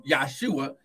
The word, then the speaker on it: Yahshua: In his earthly ministry, he was given an earthly name, Yahshua. It would not have been Jesus as we pronounce it, because there's no J in the Hebrew language. It was Yahshua.